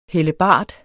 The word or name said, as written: hellebard